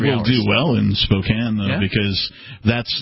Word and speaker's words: Spokane: I want to say welcome to KXLY AM 920 and FM 100.7 in Spokane, Washington.